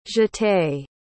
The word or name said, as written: jeté